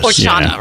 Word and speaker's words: horchata: And it's spelled, it's spelled again, O-R-X-A-T-A. – Right. – The drink itself is with an H, but the H is silent. – I guess. – Horchata, right? – Yeah.